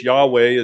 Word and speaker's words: Yahweh: which is we show it with the YHWH, Yahweh is how we would pronounce it.